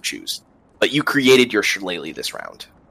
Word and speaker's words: Shillelagh: Sure, it's pronounced Shillelagh.